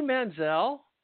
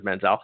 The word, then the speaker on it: Manziel: But the only thing I've got to say right now is Johnny Manziel is really how it's supposed to be pronounced, but everybody says Manziel.